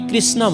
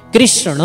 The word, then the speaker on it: कृष्ण: हामी कृष्ण भन्छौँ नि, कृष्ण उच्चारण गल्ती हो, गलत हो, कृष्ण उच्चारण गर्नुपर्छ, णको उच्चारण हुन्छ।